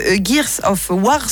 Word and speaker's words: Gears of War: On termine avec Gears of War. C'est bien ça, je le prononce juste. – Oui, c'est ça, c'est juste.